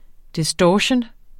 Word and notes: distortion: [ disˈdɒɕən ]; [ desˈ- ]

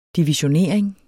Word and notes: divisionering: diviɕoˈneɐ̯ˀeŋ

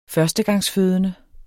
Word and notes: førstegangsfødende: [ -ˌføːðənə ]; [ -ˌføˀðənə ]

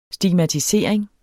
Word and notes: stigmatisering: sdimatiˈseˀʁeŋ; sdigma-

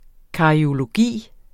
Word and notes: cariologi: kɑɐ̯ioloˈgiˀ

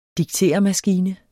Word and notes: diktermaskine: [ digˈteˀɐ̯- ]

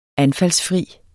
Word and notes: anfaldsfri: [ ˈanfals- ]

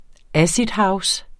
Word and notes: acidhouse: ˈasidˌhɑws; ˈased-

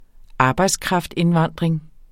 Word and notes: arbejdskraftindvandring: ˈɑːbɑjds-

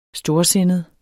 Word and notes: storsindet: -ˌsenəð; -ˌsenˀəð

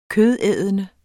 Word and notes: kødædende: [ -ˌεːðənə ]; [ -ˌεˀðənə ]

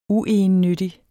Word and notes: uegennyttig: [ ˈuˌejənˌnødi ]; [ ˈuˌɑjənˌnødi ]

